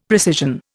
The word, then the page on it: precision: Indian